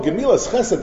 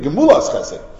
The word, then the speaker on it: Gemulas Chesed: So the old Tom was convinced, oh, Gemilas Chesed, that's the way we pronounce it in Hungary. The real, it's be Gemulas Chesed.